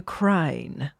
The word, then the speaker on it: Crown: The lady who played the Crown, she goes, you have to say it like the crine. The crine. Like they, yeah, like half past seven.